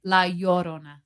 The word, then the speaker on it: La Llorona: Did I say it right? Yes. You're making fun of me. – I was trying that too. It's La Llorona.